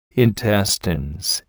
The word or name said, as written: intestines